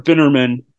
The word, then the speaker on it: Binamin: This poor Mr. Binamin uh, rhymes with cinnamon, who comes with her from Florida.